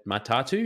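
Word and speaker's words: Matatū: the M- Matatu, is that how you pronounce it? – Yeah.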